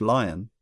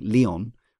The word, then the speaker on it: Leon: But also, when I learned that Leon Rafal's name was not pronounced Lion, it is canonically Leon, I thought, well, I should learn Leon.